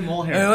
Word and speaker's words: Mjolnir: And then after, when she destroys Mjolnir, or I don't know how to pronounce it. She said Mjolnir.